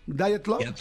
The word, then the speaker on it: Dyatlov: Eh, primero, eh, bueno, de las diferentes historias, las, la Mataviejitas, el encubrimiento de Roswell, el paso Die Club, ¿lo dije bien? Dyatlov. No sabemos, es ruso.